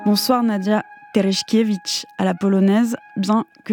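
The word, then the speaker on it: Tereszkiewicz: Parce que je vous ai demandé comment le prononcer, et comme moi je suis à moitié polonaise, vous m'aviez dit à la polonaise ça donne quoi, je vous ai dit Terechkevich. – Je préfère comme ça.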